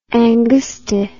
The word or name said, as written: angusti